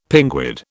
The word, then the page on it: pinguid: British English